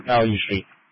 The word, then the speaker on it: Ballou: B-A-L-L-O-U Street. Value Street.